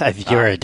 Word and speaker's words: Azure: It's a color. So the only thing that matters is how the interior designers pronounce it. And it's Azure, darling.